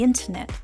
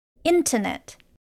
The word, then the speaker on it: internet: Internet. Sometimes when a native speaker speaks quickly, they drop the T sound at the end. So, internet could sound like internet.